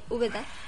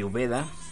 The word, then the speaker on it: Úbeda: Su nombre verdadero, Joaquín Ramón Martínez Sabina, nacido en 1949 en la ciudad de Úbeda. – Así es, él es el, flaco de Úbeda. – Eh, Úbeda, Úbeda, ¿no? – Úbeda, ¿sí es Úbeda? – Úbeda, ¿tiene acento en la U?